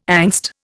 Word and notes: angst: Indian

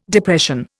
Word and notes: Depression: Indian

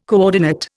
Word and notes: coordinate: Indian

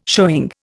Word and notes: showing: Indian

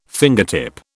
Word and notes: fingertip: British English

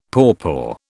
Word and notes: pawpaw: British English

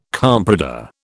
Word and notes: comprador: British English

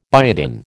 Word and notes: biotin: American English

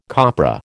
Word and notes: copra: American English

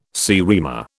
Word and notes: seriema: British English

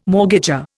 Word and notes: mortgager: Indian

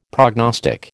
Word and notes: prognostic: American English

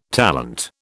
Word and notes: talent: British English

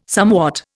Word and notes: somewhat: Indian

